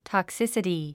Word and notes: toxicity: tɑksísəti; トクセサティ

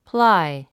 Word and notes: ply: plái; プラァイ

